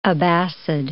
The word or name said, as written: Abbasid